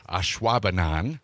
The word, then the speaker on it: Ashwaubenon: Ashwaubenon.